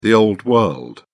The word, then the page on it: old-world: British English